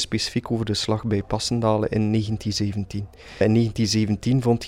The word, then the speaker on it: Passendale: We zitten bij u in het Passendale Memorial Museum, zeg ik dat goed? – In het Memorial Museum Passendale 1917.